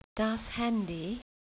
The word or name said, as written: Handy